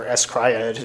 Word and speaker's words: Scryed: We were talking a little bit and we discussed a couple of his roles, and I mentioned uh, Scryed or Escryed, if you want to pronounce it with the Bro, hyphens.